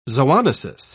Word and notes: zoonosis: British English

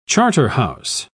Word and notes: charterhouse: British English

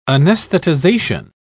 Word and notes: anesthetization: British English

